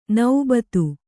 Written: ನೌಬತ್ತು

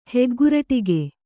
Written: ಹೆಗ್ಗುರಟಿಗೆ

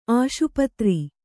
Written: ಆಸ್ಪತ್ರೆ

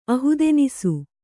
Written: ಅಹುದೆನಿಸು

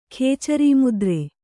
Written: ಖೇಚರಿಮುದ್ರೆ

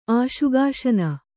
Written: ಆಶುಗಾಶನ

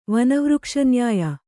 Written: ವನವೃಕ್ಷನ್ಯಾಯ

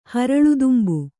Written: ಹರಳುದುಂಬು

